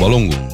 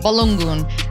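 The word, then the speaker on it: Balogun: Flo, and there's a proper way to say his name. – Balungun. – Balungun.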